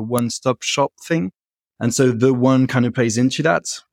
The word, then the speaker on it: Theone: Theone. Yeah, there you're saying that right. You can say as you want to. It's a play on words. The one put together, Theone.